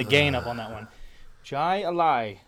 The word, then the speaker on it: Jai Alai: You see, the AI is pronounced exactly the same at the end. Jai Alai.